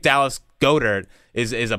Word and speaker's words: Goedert: Is it Goedert? – I believe it's Goddard.